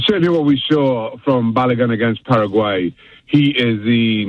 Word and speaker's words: Balogun: Did I say that right, or am I messing up that name? – Balogun. You got it right, Myron.